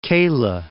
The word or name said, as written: Calah